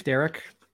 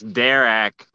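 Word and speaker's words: Darak: Derek? – I think it's Derek, because it's D-A-R-A-K, so it wouldn't...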